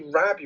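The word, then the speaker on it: Rabe: And it stars Ben Affleck, Ty Sheridan, Daniel Ranieri, Lily Rabb? – I am not sure. – I'm going to say Rabb, you don't pronounce the E, but...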